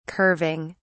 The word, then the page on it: curving: British English